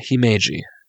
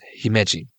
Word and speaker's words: Himeji: It's not Himeji. I always get it's almost there's no I there. Uh, it's Himeji or Himeji.